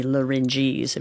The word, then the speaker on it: larynges: That would be larynges, if you want to talk about many of them.